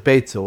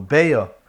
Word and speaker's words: Beitza: In Prishborg, and the other Hungarian yeshivas, one of the Masechtas that was a favorite was Maseches Beitza, or Beya, if you don't want to pronounce it that way, as a minig by some to call Maseches Beya.